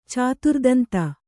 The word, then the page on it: ಚತುರ್ದಂತ: caturdanta